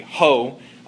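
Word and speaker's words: ho (ὅ): And so I put the Greek first, which is the word O there is the Greek word for which, or Rasmian pronunciation would be ho, Greek word for which.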